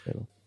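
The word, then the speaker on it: Pääbo: Svante Pabo, I think. – Pabo. I think it's Pabo. – Pabo, you're right. I think it's Pabo.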